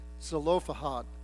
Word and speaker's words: Zelophehad: I realize that's not the way it's pronounced. It's pronounced Zelophehad. Well, again, if you're Hebrew, you probably pronounce it a little bit different, but I had inadvertently taken a syllable out of there, and so I'm working hard to try to put it back in.